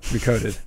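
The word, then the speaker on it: Re:coded: How do you say that? Recoded. recoded, just recoded.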